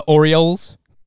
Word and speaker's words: Orioles: The Orioles. – Double stuffed. Next question. – I'm going out of my way to pronounce the L because I know that you call me out for it.